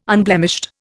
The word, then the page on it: unblemished: Indian